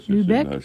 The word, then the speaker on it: Lübeck: C'est Maud Lübeck. Je crois que ça se prononce comme ça. – Oui, Lübeck. C'est là comme la ville, oui.